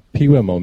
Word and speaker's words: Pewamo: And May 18th, we're playing out in Pewimo, Michigan at Kramer's Bar. – What was that name again? – It's spelled P-E-W-A-M-O. So I'm assuming it's pronounced Pewimo.